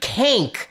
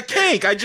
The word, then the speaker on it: Kank: And how could you forget Kank? I'm like, Kank? – Yeah, Kank. She wore a hat. That's all I know. – Rhymes with skink.